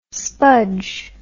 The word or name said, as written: spudge